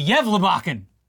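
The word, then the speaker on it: Gävlebocken: Break out the stockings, the candy canes, and eggnog, because the Yevlebakken, did I say it right? – You actually did, for the first time ever.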